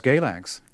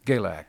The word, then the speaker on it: Galax: Early on, you went to a town, I don't know how to say the name of the town, but it looks like Galax Yeah, it's Galax. – Galax.